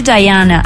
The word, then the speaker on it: Diana: Diana, ¿no? Dirty Diana, así, esa es la verdadera pronunciación de Michael Jackson en su era más rocker.